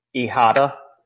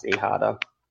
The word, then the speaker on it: Hijada: My name is Gino Ihada. That's how you pronounce it. It's spelled H-I-J-A-D-A. It's pronounced Ihada.